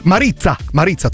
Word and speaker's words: Марица: Это Мариза. Марица, точнее. Как пицца. Марица.